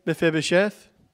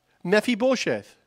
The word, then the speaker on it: Mephibosheth: Now, some people query over the way that his name is pronounced. I say Mephibosheth. Julie says Mephibosheth.